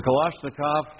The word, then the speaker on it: Kalashnikov: Some people are not so understanding, and I'm sure in places like the Soviet Union, you know, it's a Kalashnikov or whatever it's called. That's how it's pronounced, Terry. It's a Kalashnikov to the head.